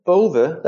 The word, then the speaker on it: Bove: That's the M Bove.